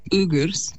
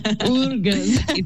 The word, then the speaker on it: Uyghurs: How do you pronounce it? Uyghurs, right? And those suffering across Africa. How do we stand firmly with Palestine while also recognizing that there's others out there that are struggling and they're connected also? – Yes, so it's actually pronounced Uyghurs.